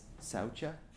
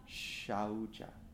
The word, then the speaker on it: saucha: So the first one looks like it says saucha, but it's a tough, like Sanskrit is a tough word. So the actual way to pronounce that is shaucha, which is hard and weird and awkward. And I'm going to say it wrong a lot, okay? Because I've for years said it wrong.